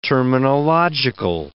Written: terminological

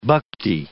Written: bhakti

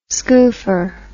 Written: skoofer

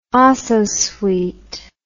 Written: ososweet

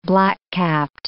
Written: black-capped

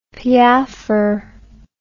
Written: piaffer

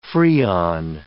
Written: Freon

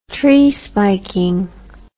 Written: tree spiking

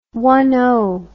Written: one number service

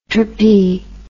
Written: tropy

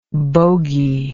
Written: bogy

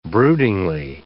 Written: broodingly

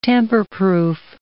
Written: tamperproof

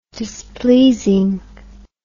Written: displeasing

